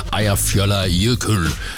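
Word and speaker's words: Eyjafjallajökull: Eir Fjöller Jökull. Mener jeg jo, at den korrekte udtale på et islandske vulkan, der gik i udbrud det her år, skabte en askesky, der nedlagde flytrafik i massevis.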